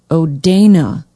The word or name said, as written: odanah